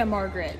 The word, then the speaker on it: Margaret: Well, do you pronounce your name the way it's spelled? – Yeah, Margaret. Yep. – But your brand is? – Margaret Knapp.